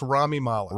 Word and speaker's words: Rami Malek: I think it's Rami Malek.